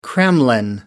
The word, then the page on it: kremlin: American English